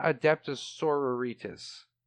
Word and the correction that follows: Adepta Sororitas: I have been mispronouncing it this whole time. It is Adepta Sororitas.